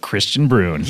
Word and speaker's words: Kristian Bruun: Kristian Bruun.